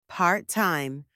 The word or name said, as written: part-time